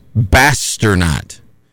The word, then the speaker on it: Basternaut: It rhymes with astronaut. It is Basternaut. So Bass, and then the rest of the way is spelling out astronaut.